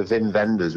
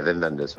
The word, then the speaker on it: Wim Wenders: His name begins with a W, but that's official, that's how they say it. – Yeah, Wim Wenders.